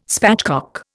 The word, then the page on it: spatchcock: Indian